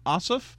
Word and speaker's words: Ossoff: 30 years old, who is running for the Democrat on the Democratic side, John Ossoff. I believe I'm pronouncing that correct. Awesome. – It is.